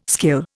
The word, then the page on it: skill: Indian